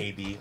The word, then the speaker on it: ABI: AB, like it's a robot. That's how the guy pronounced it.